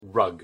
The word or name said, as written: rug